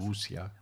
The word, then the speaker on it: Wuxia: Wuxia. – Wuxia Trilogie.